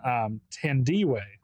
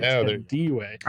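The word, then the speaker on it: Thandiwe: Yeah, in the recreation, Tandiwe, which is how her name is pronounced. Everybody, Tandiwe.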